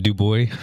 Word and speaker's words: Dubois: Illinoisans, I believe, pronounce it Dubois.